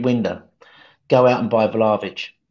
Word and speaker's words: Vlahovic: Yeah, Vlahovic.